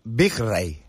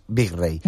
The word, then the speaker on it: Wijkstra: Ange Wijkstra. Pronúncialo tú. – Wijkstra. – Bueno, pues Un curso, pero de alemán sí que di y es Wijkstra. – Bueno, pues hagan caso porque yo seguramente lo haya pronunciado fatal de los fatales.